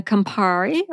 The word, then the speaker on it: Campari: And the Campari, or do you say that in a different way? – That's the way.